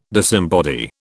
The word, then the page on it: disembody: British English